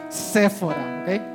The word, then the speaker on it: Séfora: No, no la tienda, es con acento en la E, Séfora, lo mismo, pero pues.